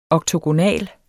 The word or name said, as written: oktogonal